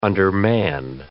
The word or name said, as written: undermanned